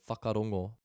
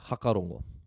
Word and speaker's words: whakarongo: So if we've got the word whakarongo, to listen, whakarongo. If you're in Northland, you'll hear hakarongo. So that F sound isn't pronounced.